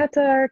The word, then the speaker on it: Qatar: Qatar.